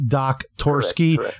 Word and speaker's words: Doktorski: Henry Doktorski.